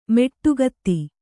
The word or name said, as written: ಮೆಟ್ಟುಗತ್ತಿ